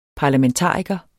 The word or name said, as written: parlamentariker